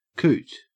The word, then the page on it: coot: Australian English